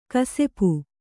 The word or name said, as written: ಕಿಸುಪು